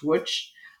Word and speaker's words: Łódź: It's spelled L-O-D-Z and pronounced Łódź, to the Łódź ghetto in Poland.